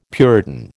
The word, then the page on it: puritan: American English